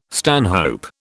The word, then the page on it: stanhope: British English